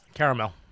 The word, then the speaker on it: caramel: Now, first of all, before we jump into the food, say that word. – Caramel. – Is that how you normally say it? – Yeah, caramel. – How do you say it? – Caramel. – Caramel. That's how I say it. I say caramel, or too. Caramel. – Because there's a damn A in there.